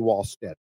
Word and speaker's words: Wallstedt: Wallstedt, according to Jesse, not Wall. I keep saying Wallstedt because that's how it's spelled. I think. – Oh, is it a V at the front? – It sounds like it's a V pronunciation.